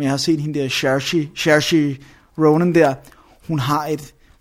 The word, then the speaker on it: Saoirse: Saoirse Ronan, som er 13 år i filmen, i hvert fald, som er Oscar nomineret for rollen.